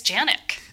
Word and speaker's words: Janiak: Janiak, or is it Janik? – It's Janik.